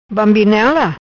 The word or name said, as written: Bambinela